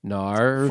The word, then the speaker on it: Gnar: Gnar. – Gnar. – For the wiki, it's spelled G N A R. – I just spelled it that Gnar. – Way. High five. Nice. Like gnarled.